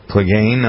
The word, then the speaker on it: Clegane: He's famous for his role as Gregor the Mountain Clegane. Is that it? Is that how you pronounce his name? – Uh-huh. – Clegane on Game of Thrones.